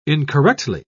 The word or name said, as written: incorrectly